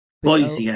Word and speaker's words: Boise: All right, if you're a fan of Superman the movie and you live in Idaho, I think it's pronounced Boise? – Boise, Idaho. – Yeah, Boise, Idaho.